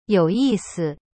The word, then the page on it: 有意思: you yi si